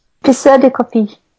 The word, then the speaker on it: pisseur de copie: How should I be pronouncing this? – Pisseur de copie.